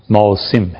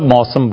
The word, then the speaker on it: موسم: اب یہ ایک لفظ موسم اور ایک ہے موسم, اصل تلفظ کیا ہے؟ موسم ہے. ہم سب موسم بولتے ہیں تو اب چل پڑا ہے, ورنہ اصل میں یہ لفظ موسم ہے.